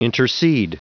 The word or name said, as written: intercede